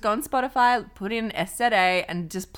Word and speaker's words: SZA: Anyway, it's SZA, but it's spelled SZA.